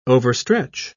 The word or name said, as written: overstretch